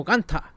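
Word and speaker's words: Gunther: Creo que parece que Walter sale como Walter en el juego y no como Ganta, que es como en verdad se pronuncia.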